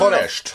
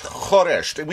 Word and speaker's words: khoresh: What the khoresh, khoresh. We spell it, well, I suppose if you transliterate it into English, it's K-H-O-R-E-S-H.